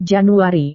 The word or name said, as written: januari